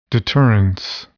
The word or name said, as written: deterrence